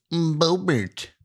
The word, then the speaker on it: Boebert: Boebert. – It rhymes with Go-Gurt.